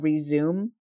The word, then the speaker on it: Rezoom: The third book is called Resume, and that's spelled R-E-Z-O-O-M,